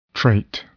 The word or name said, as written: trait